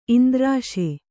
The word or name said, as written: ಇಂದ್ರಾಶೆ